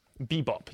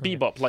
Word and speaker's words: Bebop: Bebop. Yes, right. Bebop, like doo-wop.